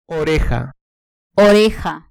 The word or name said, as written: oreja